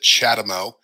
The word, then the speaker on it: CHAdeMO: It's a crazy acronym, but it sounds like I just pronounced it CHAdeMO.